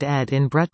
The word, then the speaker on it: Edinburgh: How to say it 1 Edinburgh UK 28,800 Ed in Brut